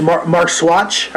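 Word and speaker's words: Marxuach: It was directed by Rod Holcomb and written by Carlton Cuse and Javier Griot Marco. Did I say that right? Probably. – I think it's Mark Swatch, I believe, but I could be wrong.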